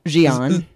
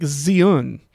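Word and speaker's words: Xi'an: It's more like Zion is how they were pronouncing it. – Oh, I thought I was hearing Jian. – Yeah, I actually can't recall now, but I know how it's spelled. It's like X, you know, XI apostrophe AN. But yeah. I thought they pronounced it like Z. The X is a Z, and then like un.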